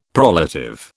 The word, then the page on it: prolative: British English